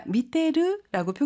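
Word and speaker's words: 미테르 (見てる): 그런데 여러분, 미테 이르가 아니라 미테르라고 표기가 되어 있잖아요. 테 이르는요, 회화체에서는 이가 생략되고 테르와 같이도 발음한답니다.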